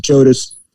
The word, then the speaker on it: Jodice: our last name is Jodice, and although we pronounce it Jodice, someone took the J-O off the front. I became Dice, right?